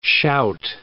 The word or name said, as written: shout